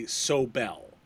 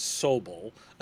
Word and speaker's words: Sobel: Europeans, particularly British, always say Sobel. It's always that. And, of course, it's Sobel.